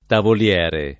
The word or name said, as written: tavoliere